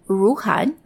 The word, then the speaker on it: Ruhnn: It's called Ruhan, that's spelled R-U-H-N-N, and it's just filed for IPO a few weeks ago here in the US on the NASDAQ.